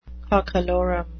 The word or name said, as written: cockalorum